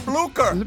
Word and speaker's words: Blücher: Blaucher. – Frau Blücher.